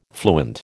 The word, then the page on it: fluent: American English